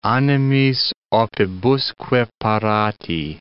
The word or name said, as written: animis opibusque parati